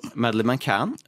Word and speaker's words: Madeline: Uh, og litt for å forklare hva det handler om, så handler det om Madeleine eller Madeline, litt usikker på hvordan man uttaler det. – Madeline McCann. – Madeline McCann, Som er en transjente som eh, sliter veldig med å være glad i seg selv.